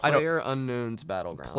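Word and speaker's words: PlayerUnknown's Battlegrounds: Did I say it right? – PlayerUnknown's Battleground.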